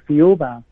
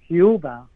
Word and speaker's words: Thiaoouba: Yes, the pronunciation of the word Thiaoouba can also be Thiaoouba, which is very similar to the pronunciation or the way how people pronounce Jehovah.